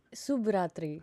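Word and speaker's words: Subratri (शुभ रात्रि): Did I say it right? Shubra Tree. – Subratri. – Subratri. – Yes!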